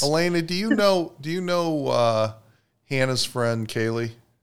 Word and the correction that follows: Callie: Callie. – I do not. – Callie, sorry. Okay, she's probably on next week, except I just mispronounced her name, so now I'm in trouble.